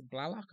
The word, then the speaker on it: Blalock: Yeah, that's Blalock.